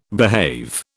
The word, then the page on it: behave: British English